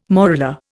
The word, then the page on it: morula: Indian